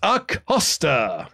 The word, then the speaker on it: Acosta: The Roller Coaster, Robert Acosta. – Pronunciation is like a coaster. It literally said, like, right after it, it says that.